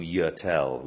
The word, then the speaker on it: Yurtel: And then we've got a super new VIP campsite that has this wonderful thing called Yurtel, which is a... – Spelled? How do we spell that? – Y-U-R-T-E-L.